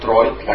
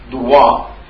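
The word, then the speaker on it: droit: لكن من الغريب حينما نأتي إلى الفرنسية نجد أن الدروات، طبعا هذه تكتب درويت لكن بالفرنسية تلفظ وتلفظ دروات،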